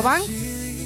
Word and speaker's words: Banks: Se escribe B-A-N-K-S. Banks.